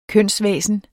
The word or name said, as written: kønsvæsen